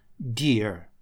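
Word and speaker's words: deer: dear. Произносится так же, как дорогой, но это олень. Дорогой у нас третья буква A в слове, а здесь, как вы видите, две I.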